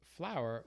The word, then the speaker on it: Flou(‑e)r: And as soon as I looked it up, I saw that the name of our company, Flou(‑e)r, which is the phonetic spelling of the word flower. Wow.